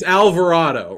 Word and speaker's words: Alvarado: I got it's Alvarado, right? That's how you say it.